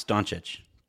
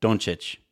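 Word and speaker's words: Dončić: You should be able to pronounce Doncic. Doncic.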